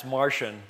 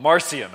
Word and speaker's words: Marcion: Now, his name is Marcion, and you'll often hear it pronounced Marcion, like he was like one of the Three Musketeers, and it's French. But I understand vulgar Latin, it's Martian, but that sounds like he came from outer space. So I don't know what we're going to call him. Marcion, I think, is the best pronunciation.